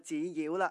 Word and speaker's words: 纸鸟: 由于广东人在口语上呢或多或少会将一个词语最后那个字的读音呢就提高一点，所以纸鸟呢就变成了纸鸟了。